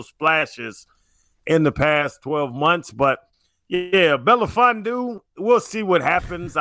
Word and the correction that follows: Melifonwu: I don't know if I'm saying his name right. – You're not. – Like, we'll see if he can replace – I'm not. Belafondu, what's his name? – No, Melifonwu. Melifonwu.